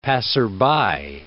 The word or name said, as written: passerby